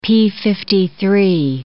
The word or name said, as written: p53